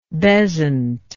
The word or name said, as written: bezzant